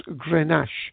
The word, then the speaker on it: Grenache: Okay, so it's pronounced Grenache, and it's one of the most widely planted red grape varieties in the world.